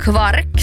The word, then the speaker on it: kvark: Och sen då mjölkprodukten med omstritt uttal kvark, som ju också många uttalar som kvarg. Jo, det fattar jag inte. – Nej, jag fattar inte. Alltså sluta genast. – Jo. – Varför? – Bra fråga, Joel. Varför? Nu ställer du de viktiga frågorna. – Nu. – Varför ska man uttala kvark som kvarg?